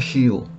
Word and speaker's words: Heel: немецкой фирме Хейл. H-E-E-L по-немецки. Или H-E-E-L. По-английски это читается Heal.